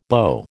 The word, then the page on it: BO: American English